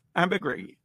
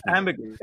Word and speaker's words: ambergris: Ambergris. No, I think the S is silent if you're a Frenchman. – Ambergris.